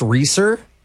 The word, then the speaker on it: Reeser: Yeah, my name is Alex Reeser. – Reeser. Can you spell that, please? – Yeah, R-E-E-S as in Sam, E-R.